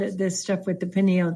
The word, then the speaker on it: pineal: And the third eye relates to the pineal gland, and the, or you can say pineal, however you want to say it.